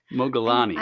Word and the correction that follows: Modigliani: I know who that is, but that's not how you say it. – And his wife. Modigliani.